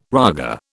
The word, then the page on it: raga: British English